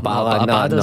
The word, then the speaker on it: Abada: Thomas said Abala. I don't think he said Abada, didn't he? Is that Abada? – Abada is the correct answer.